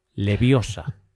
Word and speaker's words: Leviosa: debe pronunciarse la voz como llana, esto es, con acentuación prosódica en la penúltima sílaba. Leviosa.